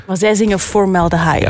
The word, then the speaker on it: formaldehyde: Formaldehyde? – Ja, dat is perfect uitgesproken eigenlijk.